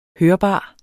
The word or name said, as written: hørbar